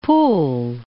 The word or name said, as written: Poole